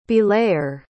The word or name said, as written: belayer